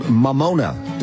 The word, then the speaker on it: Mamona: She's got a special out right now on Hulu called Mamona.